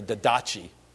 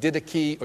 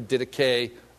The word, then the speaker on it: Didache: I used to call it the didachi, but that's not really right. You pronounce it didache or didache. I like to say didache.